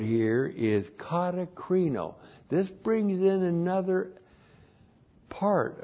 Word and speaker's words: katakrino: Sounds like katakrino. K-A-T-A-K-R-I-N, long O, katakrino.